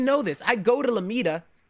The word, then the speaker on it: Lomita: Lomita, California. – Yeah, that's how I say it. Lomita.